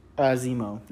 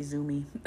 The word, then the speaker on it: Zemo: Zemo. – I always say Zoomy.